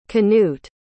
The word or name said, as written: cnut